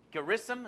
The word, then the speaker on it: Gerizim: Gerizim, is that how you pronounce it? Gerizim, okay.